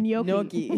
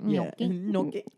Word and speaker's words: gnocchi: Wait, how did you say it the first time? Gnocchi? – Gnocchi. – Gnocchi. That's gnocchi. How I want to say it now. – How do you say gnocchi. – It? – It's gnocchi. Gnocchi. – Gnocchi. – Gnocchi. – Gnocchi. – I mean, there's a G in there.